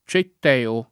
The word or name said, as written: Cetteo